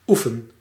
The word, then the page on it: oefen: /ˈu.fən/